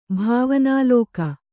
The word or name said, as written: ಭಾವನಾಲೋಕ